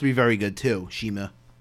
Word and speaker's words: CIMA: It's actually pronounced Shima if you look on Wikipedia. – Shima.